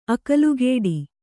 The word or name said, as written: ಅಕ್ಕಲಕೋಟೆ